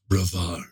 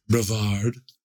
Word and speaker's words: Brevard: No, and I just love the way that's how it's pronounced. Brevard. – Mm-hmm. – It sounds like Brevard by Fabergé. What are you wearing? Brevard.